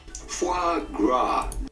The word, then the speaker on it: foie gras: The Japanese pronunciation is foie gras. In French, this is pronounced foie gras.